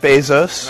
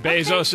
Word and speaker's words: Bezos: How's it pronounced, Bezos or Bezos? – Here we go. This is actual audio of him. – Hello, my name is Jeff Bezos. – All right, Bezos, okay.